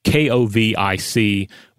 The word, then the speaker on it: Kovic: This is uh, K O V I C with the uh, the last part having a, a ch uh, pronunciation like in chocolate.